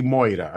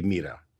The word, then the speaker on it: μοίρα: Είναι αυτό που λέει η Μόιρα, αντί για να πει η Μοίρα.